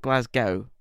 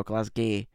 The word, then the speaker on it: Glasgow: And if there's any American listeners, it's not, you pronounce it Glasgow, not Glasgow or Glasgay. Glasgow. – Glasgow.